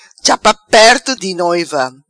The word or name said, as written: Chapa Perto Di Noiva